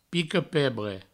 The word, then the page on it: pica-pebre: Provençal; pico-pebre